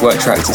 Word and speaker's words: WRKTRX: I'm pretty sure that's supposed to be pronounced Work Tracks. It's WRKTRX Rhythm 1.